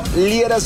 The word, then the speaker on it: Liera: Fernando Liera, se escribe L-I-E-R-A.